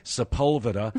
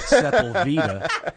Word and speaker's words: Sepulveda: You know that they pronounce it Sepulveda, not Sepulveda.